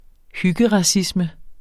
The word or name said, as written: hyggeracisme